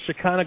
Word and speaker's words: Shekinah: I always say it wrong. I want to say Shekinah for some reason. I don't know. Shekinah. The Shekinah glory settled on the temple.